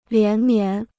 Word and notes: 连绵: lián mián

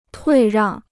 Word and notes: 退让: tuì ràng

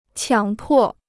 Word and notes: 强迫: qiǎng pò

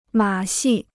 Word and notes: 马戏: mǎ xì

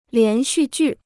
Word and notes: 连续剧: lián xù jù

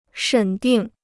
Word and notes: 审定: shěn dìng

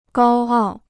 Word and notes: 高傲: gāo ào

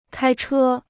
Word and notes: 开车: kāi chē

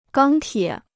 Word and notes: 钢铁: gāng tiě

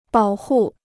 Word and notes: 保护: bǎo hù

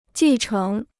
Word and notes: 继承: jì chéng

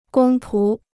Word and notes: 公仆: gōng pú

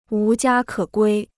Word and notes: 无家可归: wú jiā kě guī

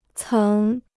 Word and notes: 曾: céng

